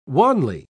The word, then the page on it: wanly: British English